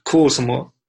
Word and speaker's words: Kuusamo: So you're from Finland, Lapland, from Kwasuma. I pronounced that right? – Yeah, almost there. It was Kuusamo.